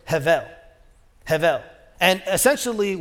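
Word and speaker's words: hevel (הבל): Okay, here's what he said about this word hevel. He says, one must aspirate twice with the initial huh sound and then again with the soft bet pronounced as vel.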